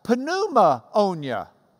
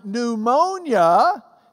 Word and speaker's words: pneumonia: You might have pneumonia. That's why pneumonia is spelled P-N. Pneumonia, because it's the ammonia of the pneuma.